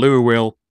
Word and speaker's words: Louisville: I lived in Louisville, but I learned the hard way while I was living there. It's called Louisville. Well, it's spelled fucking Louisville.